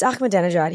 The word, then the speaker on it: Ahmadinejad: And I, I pr- mispronounced his name, and I remember him being like, "It's Ahmadinejad.